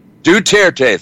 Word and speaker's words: Duterte: Is that how you pronounce that guy over in the Philippines? Duterte. Thank you, Mike.